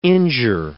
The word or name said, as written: injure